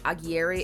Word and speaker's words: Aguirre: I believe it's pronounced Aguirre.